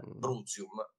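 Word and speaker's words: Bruttium: Brutium, in pronuncia ecclesiastica.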